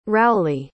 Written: rowley

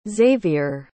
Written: xaviere

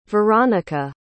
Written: veronika

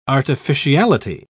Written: artificiality

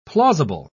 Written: plausible

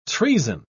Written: treason

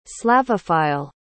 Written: slavophile

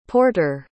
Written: porteur

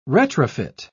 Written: retrofit

